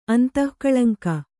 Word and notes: ಅಂತಃಕಳಂಕ: Kannada

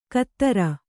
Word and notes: ಕತ್ತರ: kattara

